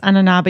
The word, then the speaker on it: ananas: Oh, well, in French, it's ananas, but you just don't pronounce the S.